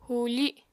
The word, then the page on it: hùufi: apatani